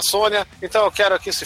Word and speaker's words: Sonja: Ah, é, diz a pronúncia correta que é Sônia, ah, se escreve Sonja, o gibi aqui, a molecada sempre falou Sonja,